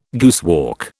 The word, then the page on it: guesswork: British English